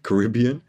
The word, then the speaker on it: Caribbean: Caribbean. Caribbean.